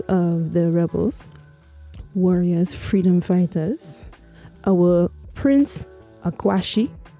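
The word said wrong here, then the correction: Akwasi: Am I saying it right? – Akwesi. – Akwesi. Right.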